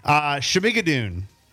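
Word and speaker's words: Schmigadoon: It is Shmigadoon.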